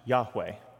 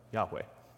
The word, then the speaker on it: Yahweh: But the scholarly consensus, and it's basically universal, is it should be pronounced Yahweh. You guys have probably all heard that before, Yahweh.